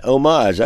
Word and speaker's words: homage: Homage, I love that word. The H is silent, yeah, and you can't hear it either.